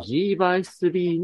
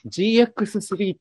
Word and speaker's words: G by 3: 今ね、言ってるのが、あの、お勤めされている人気アンダーウェアブランドの G by3 のお仕事の話をね、あれは、私、あの、最初 GX3 って読んでたんだけど、 G by 3 っていうのね。